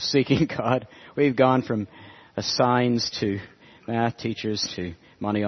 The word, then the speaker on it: Sines: Anyway, he has a sign company, and his name is Signs. But now, John, this one's for you. This one's for you. Before he ran the sign company, he was a mathematics teacher, and his name is spelled S-I-N-E-S.